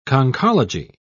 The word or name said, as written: conchology